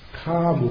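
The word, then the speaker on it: kāma: So the attachment is very clear in the Pali, it's karma upadana, the K long A-M-A.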